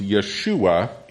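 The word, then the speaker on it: Yeshua (ישוע): For example, Jesus' name is pronounced Yeshua in Hebrew.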